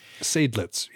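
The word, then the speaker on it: Seydlitz: But while the cavalry was waiting to charge, their head, uh, his name was Saditz. I'm no way I'm pronouncing that correctly. I'm sorry. Sadlitz, yeah. Sadlitz uh,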